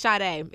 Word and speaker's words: Sade: It's Sade. It's not Sade. I've been saying Sade for ever.